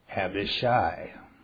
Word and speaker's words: Abishai: Abishai. It's not a long A. Normally I would say Abishai, but it's not.